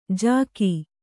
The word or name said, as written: ಝಗ